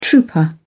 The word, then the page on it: trouper: British English